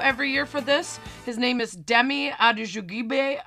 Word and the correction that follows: Adejuyigbe: I totally butchered his last name, but search Demi. – I would have nailed it. – Yeah. Search Demi on YouTube. It's spelled Adejuyigbe. Adejuyigbe.